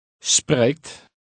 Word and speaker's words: spreekt: Spreekt. Spreekt. Spreekt. – Again, note the sound of the Dutch R.